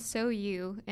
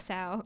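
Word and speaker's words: SOU: And so it's called So You, and you need to pay attention to that pronunciation. Don't call it Sow. – Yes. How's it spelled? – It's S-O-U.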